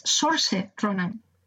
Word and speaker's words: Saoirse Ronan: Yo he aprendido a pronunciarlo y creo que es Saoirse Ronan.